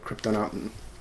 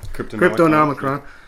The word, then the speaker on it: Cryptonomicon: And I think last summer I read Kryptonomicon. Yeah, I just butchered that one. – Kryptonomicon.